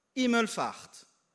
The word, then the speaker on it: Himmelfahrt: Christ Himmelfahrt. Himmel, hein, faut prononcer le H.